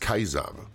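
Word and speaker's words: Caesar: Die originale Aussprache lautet übrigens Kaiser. Die Römer sprachen Ä ungefähr wie wir Ei und C immer als K.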